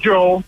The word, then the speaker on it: Joe: Well, my name's Joe. J-O, just a plain old Joe.